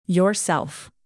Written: yourself

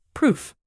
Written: proof

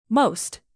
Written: most

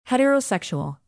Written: heterosexual

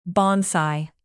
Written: bonsai

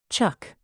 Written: chuck

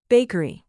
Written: bakery

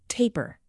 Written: taper

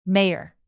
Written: mayor

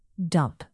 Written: dump

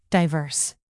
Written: diverse

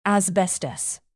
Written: asbestos

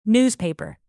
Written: newspaper

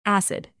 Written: acid

